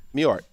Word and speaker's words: Mewhort: I think it's Mewhort. – No. That's in Mewhort. – No. Mewhort. The W is basically silent.